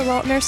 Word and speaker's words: Lautner: It is technically Lautner.